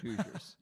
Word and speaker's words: Hoosiers: You always mispronounce things. Hoosiers.